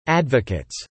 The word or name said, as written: advocates